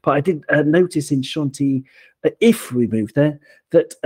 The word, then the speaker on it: Chantilly: It's actually pronounced Chantilly in French. You sort of pronounce the double L as a Y, Chantilly. Like Bastille doesn't exist in French. It's Bastille, same kind of thing, double L. Pronounce it like a Y.